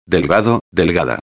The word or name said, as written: delgado-a